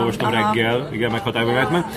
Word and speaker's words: Reitman: Ivánnak mondtam volna. Iván Reitmannak. Csak ilyen németesen. – Különben jó az, hogy minden névnek legyen egy magyar kiejtése. – De nem, ez egy német kiejtés.